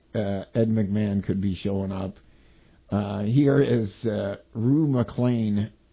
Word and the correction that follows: McClanahan: McClanahan. – McClanahan? Is that how it's pronounced? – Yes. – Rue McClanahan.